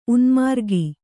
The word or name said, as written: ಉನ್ಮಾರ್ಗಿ